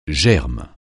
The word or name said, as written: germe